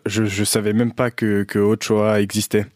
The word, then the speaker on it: Ochoa: Donc Francisco Guillermo Ochoa Magaña, plus connu sous le nom de Guillermo Ochoa, prononcé Ochoa, né le 13 juillet 1985 à Guadalajara, est un footballeur mexicain.